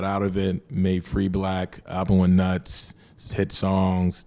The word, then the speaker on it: 6LACK: That's 6LACK, and no matter what you think, it's still pronounced Black.